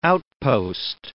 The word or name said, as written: outpost